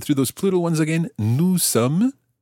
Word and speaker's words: sommes: Nous sommes. – Nous sommes. – And that's spelled S-O-M-M-E-S.